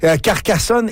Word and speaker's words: Carcassonne: Carcassonne et Foix. – Oui, c'est bien ça. – Oh, ouf, j'ai eu peur. J'ai hâte de voir. – Vous connaissez bien la géographie française. – Oui, paraît-il que Carcassonne, c'est très beau.